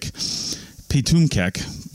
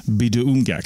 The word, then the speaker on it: Pituamkek: It looks like Pitumkek, but it's pronounced Biduumgek.